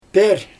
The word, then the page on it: per: Xyzyl